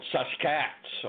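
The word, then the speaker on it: Sasq'ets: the word Sasquatch itself was a mispronunciation and a misspelling of a Chehalis word called Saskats, or basically take the word basket and drop the B and add S-A-S.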